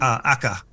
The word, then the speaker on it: Acre: Akka. It's also called Acre. Okay. It's spelled like Acre. But it's AKA or ACRE.